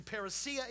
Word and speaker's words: parousia: You may have heard me mispronounce it lots of times when I get happy and say parousia. It's actually parousia in the Greek.